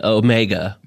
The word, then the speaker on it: Omega: The Omega watch. – Oh, okay. – Yes, which is how it's pronounced.